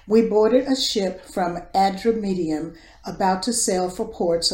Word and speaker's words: Adramyttium: It's the place where they sailed from, and it's called Adramyttium.